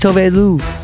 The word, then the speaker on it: Tove Lo: I said her name wrong in the interview. Don't judge me. It's Tove Lo.